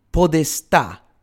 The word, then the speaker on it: podestà: And so, as the 12th century ended and the 13th progressed, the figure of the podestà, that's P-O-D-E-S-T with an accent on the A, with a stress on the A, was affirmed.